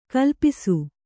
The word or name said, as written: ಕಾಲಪಾಶ